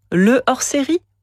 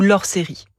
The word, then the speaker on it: hors-série: Dit-on le hors-série ou l'hors-série ? Eh bien, la réponse vient naturellement, le hors-série, comme le hors-d'œuvre ou le hors-la-loi. Le déterminant le ne s'élide pas, c'est-à-dire que sa voyelle ne disparaît pas, ce qui signifie que le H est aspiré.